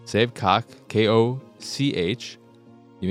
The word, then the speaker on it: Koch: And aren't there other pronunciations of K-O-C-H? You may be right. And I believe Coke Media is Coke Media. However, the game says its pronunciation of K-O-C-H, and it is indeed cock. And sometimes it's cock, but it's either a version of cock or caulk.